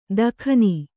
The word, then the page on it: ದಖನಿ: dakhani